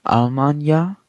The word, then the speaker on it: Almanya: Dann kommt der Filmtitel, also Almanya. So ist die türkische Aussprache, mehr betont.